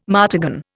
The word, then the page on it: martagon: Indian